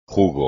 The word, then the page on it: jugo: Spanish